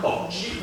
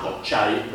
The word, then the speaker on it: cocci: Quindi gli inglesi scrivono come i latini ma pronunciano come gli inglesi, e quindi non si pronuncerà cocci ma cocciai, perché nella lingua inglese americana la I si pronuncia ai.